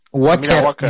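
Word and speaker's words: Admira Wacker: Eh, lo que no lo recuerda era un austríaco así medio rubio mm, chupadito, que bueno, estuvo en el, a ver si lo digo bien, Admira Watch, Admira Walker, Admira Walker. – Admira Walker, ese, de Austria,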